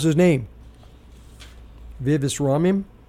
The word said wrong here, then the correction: Ramaswamy: And Ramaswamy, sorry, Vivek messed me up. Ramaswamy.